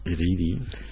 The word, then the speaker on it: ridi: Tenemos que recordar que en Esperanto la R fuerte no existe. Es siempre R. Ridi. – Los castellanohablantes tenemos esa dificultad, que la R al principio la pronunciamos R. Y en Esperanto no existe el sonido R. Existe el sonido R solamente. Así, de ridi.